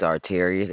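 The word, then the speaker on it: Arterious: It's Arterius. They call me Art, though. – How do you spell that? – A-R-T.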